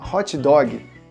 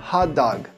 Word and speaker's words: hot dog: Dói até ouvido um pouco essa pronúncia. Se você quiser falar direitinho, repete comigo a pronúncia hot dog. Hot dog. O inglês, quando você tem o H, você solta só o ar, você não faz o som de R. Você solta o hot.